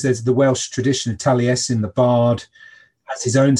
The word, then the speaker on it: Taliesin: And it comes in with, or along with it comes Taliesin, if I'm saying that right. – Yes, yes.